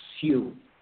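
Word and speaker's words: Sjöö: The best approximation that I've heard is Sue, saying the J kind of like a Spanish usage, uh, like a Y. Sue.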